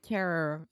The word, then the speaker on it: Carreyrou: Carreyrou.